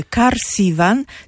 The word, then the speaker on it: Karsivan: Przez K, tak jak mówię, Karsivan, tak się tą nazwę pisze i tak się tą nazwę wymawia.